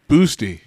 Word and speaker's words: boasty: Boosty. – Boosty. Yeah. – What? What did you say? How do you spell it? – B-O-A-S-T-Y. – Boosty.